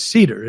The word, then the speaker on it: Seder: My last name is Cedar. It's like literally the Seder. – It's wood. – Right? Well, it's wood. S-E-D-E-R. – Cedar wood. – No. – What? – No. Seder. It's spelled like Seder.